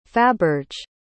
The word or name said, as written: faberge